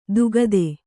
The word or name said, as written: ದುಗದೆ